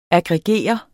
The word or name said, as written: aggregere